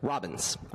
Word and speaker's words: Robbins: Can you say your last name one more time? – Yes, sir. Robbins. R-O-B-B-I-N-S.